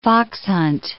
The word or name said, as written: foxhunt